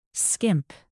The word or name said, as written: skimp